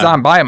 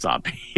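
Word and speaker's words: Zombi: It's just pronounced Zombie. It's not Zombie. – It's Zombie. – I do like that you call it that, but it's just Zombie. There's just no E on it.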